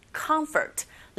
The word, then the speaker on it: comfort: Comfort는 첫 번째 음절에 강세가 있고, F 발음하실 때 comfort 하시면 안 되죠. Comfort. 긁어주세요. 윗니로 아래에서 살짝만 닿으면서 이렇게 긁어주시면 됩니다.